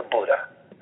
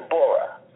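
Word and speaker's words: Bura: That's the way they pronounce it in Croatia. They call it the Buddha. And I think in Italy they call it the Bora.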